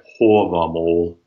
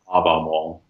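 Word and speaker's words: Hávamál: where the long A is rounded, hence why I say hovamol, not havamol.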